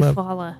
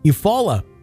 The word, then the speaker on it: Eufaula: Across the lake was another town, which I can't pronounce the name Eufaula. – Of. Eufaula, Alabama. There we go.